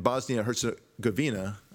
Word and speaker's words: Bosnia Herzegovina: In Bosnia Herzegovina, I hope I'm pronouncing that right.